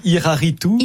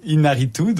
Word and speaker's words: Iñárritu: Iñárritu. – Iñárritu,